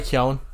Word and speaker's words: Keoghan: Barry Keoghan. Did I say that right? Is it Keogh or Keoghan? sorry, uh, Barry Keown.